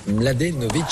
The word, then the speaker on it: Mladenovic: Kristina Mladenovic, 24 ans, et comme Kristina Mladenovic au début, et ben c'est pas forcément simple à prononcer. Pour que vous puissiez être à l'aise avec son patronyme pour briller à la machine à café tout à l'heure, je vous propose quelques techniques. Vous avez par exemple la méthode Laurent Delahousse sur France 2 qui lui assure plat du pied sécurité, vous décomposez bien les syllabes.